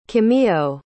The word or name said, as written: kimiyo